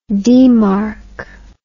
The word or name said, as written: demarch